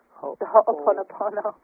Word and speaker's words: Ho'oponopono: The Ho'oponopono. – Ho'oponopono? – Yes, that's right, ho'o. Ho'o means to make, and you're making right.